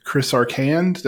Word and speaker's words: Chris Arcand: Today anyways, we are joined by Chris Arcand. Did I pronounce it right? – Yeah.